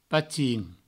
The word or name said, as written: patin